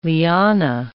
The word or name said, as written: liana